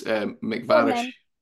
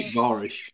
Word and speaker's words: MacVarish: It's like it's Bath and Bath. It's MacVarish. So you say Mathew MacVarish.